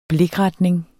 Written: blikretning

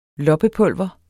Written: loppepulver

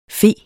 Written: fe

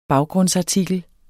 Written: baggrundsartikel

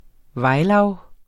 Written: vejlav

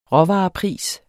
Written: råvarepris

